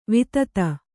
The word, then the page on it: ವಿದಿತೆ: kannada